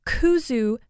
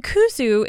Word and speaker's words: kudzu: Another thing to consider is kuzu powder. And kuzu is spelled K-U-D-Z-U, sometimes just K-U-Z-U.